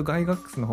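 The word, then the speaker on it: Gygax: 英語で発音するとガイガックスの方があのその音に忠実らしい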